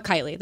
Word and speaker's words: Kylie: At Seagull Kylie. That's it. And my first name is spelled K-Y-L-I-E.